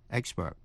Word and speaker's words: expert: Vậy expert là danh từ hay tính từ, phát âm như nhau, đều nhấn vần đầu, expert. Và danh từ expertise, E-X-P-E-R-T-I-S-E, expertise, là nhấn vần ba, có nghĩa là tài năng chuyên môn.